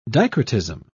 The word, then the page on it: dicrotism: British English